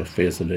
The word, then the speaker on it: Fiesole: Fiesole, And I used to pronounce it wrong until this artist came down to examine it. It's called it Fiesole or something, but so Fiesole is how she pronounced it.